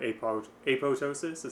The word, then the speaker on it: apoptosis: So a quick little definition on apoptosis, is that how you say it? – Mm-hmm.